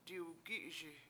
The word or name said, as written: diwgI7iSi